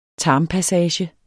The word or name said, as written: tarmpassage